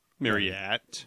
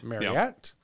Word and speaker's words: Marryat: M-A-R-R-Y-A-T. – Marriott. – Marriott.